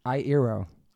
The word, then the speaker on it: Iero: But Frankie Iero. Said it right? – That was good, yeah.